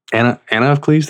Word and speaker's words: Anna of Cleves: Anna of Cleves, did I say that right? – That's it.